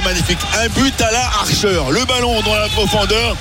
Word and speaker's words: Archer: But de Cameron Archer, que beaucoup prononcent Archer. – Archer, oui.